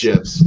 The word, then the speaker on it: gif: GIFs? Is it gifs or gifs, guys? – It's gifs, like peanut butter.